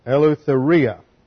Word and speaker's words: eleutheria: Eleutheria. The accent is over the iota there. It's spelled E-L-E-U-T-H. I left a letter out. T-H. E-R-I-A.